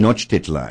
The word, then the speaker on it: Tenochtitlan: Tenochtitlan. – Did I say that right? – Yes. – Okay. Tenochtitlan.